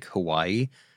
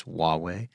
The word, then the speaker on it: Huawei: And by the way, this is the one that most people think is like Hawaii, because it's spelled Huawei, but it's Huawei, and ZTE for at least a decade.